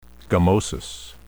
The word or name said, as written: gummosis